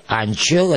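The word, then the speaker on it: Anchel: Анчел, это пишется по-румынски Анцель, да, представляется слоги его фамилии.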